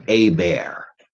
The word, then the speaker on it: Hébert: His name was Marcel Hébert, H-E-B-E-R-T, with the acute accent on the first E.